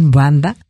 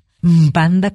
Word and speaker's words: Mbanda: Cerramos con esto el programa con Gabriel Mbanda. Mbanda con M se escribe MB seguidas. Gracias, Fortino Montaño.